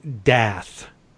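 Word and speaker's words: D'Eath: Dath, D apostrophe E-A-T-H with the silent E.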